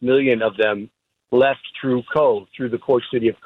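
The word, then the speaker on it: Cobh: And for anybody who really has family or roots in Ireland, anytime you mention, am I saying that correctly, the Cobb Heritage Center? – It's actually Cove. – Cove. – Like a Lake Cove, yeah. – Like Siobhan, okay. – Yeah, like Siobhan, exactly.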